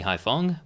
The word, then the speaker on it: Haiphong: Uh, today we're joined by the lovely and brilliant Danny Haiphong, which I apologize if I mispronounced your last name.